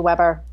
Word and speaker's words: Weber: So, Weber, am I saying it right because I'm saying it in German, or is it actually Weber? – Ironically enough, it's actually Weber.